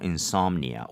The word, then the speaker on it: insomnia: Insomnia. O에 강세 주면 되겠죠? – Mm-hmm.